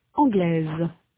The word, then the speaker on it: anglais: In sound, the feminine form anglaise has a final consonant z that is not heard in the masculine form anglais. In the masculine, the final s is not pronounced. In the feminine, s represents the sound z.